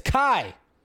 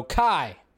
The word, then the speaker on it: Kai: Charlie Hunnam apparently revealed to fans at the Indiana Comic-Con that his character's name in Rebel Moon is Kai. That's how you say it. It's spelled K-I, so Kai. That's how you say his name.